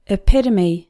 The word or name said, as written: epitome